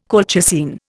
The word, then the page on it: colchicine: Indian